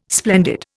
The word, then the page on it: splendid: Indian